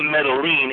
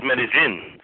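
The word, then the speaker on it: Medellín: It's not Medellin, it's Medellin. That's the way you say it.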